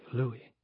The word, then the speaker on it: Louis: He pronounced it the French way, Louis.